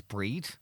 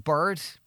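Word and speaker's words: Bríd: My mother's name is Breed, spelt the normal way, yeah. B-R-I-D. She gets called Bird, Breeda, Bridget, Breeda, and no one, not even the Irish, can get Breed right.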